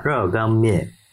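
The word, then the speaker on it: 热干面: え、熱い乾燥した麺、熱い乾麺と書いて、うん、中国ではラーガン麺という発音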